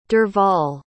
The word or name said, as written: derval